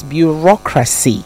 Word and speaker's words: bureaucracy: And then it's bureaucracy. That A-C-Y at the end is never racy, it's assy. Bureaucracy, meritocracy. All the, you know, A-C words are pronounced assy, all right?